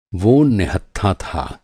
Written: निहत्था

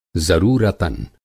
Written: ज़रूरतन

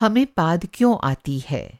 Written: पाद

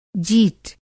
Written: जीट

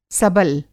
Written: सबल

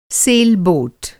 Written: सेलबोट

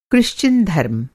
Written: क्रिश्चियन धर्म

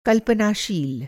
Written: कल्पनाशील

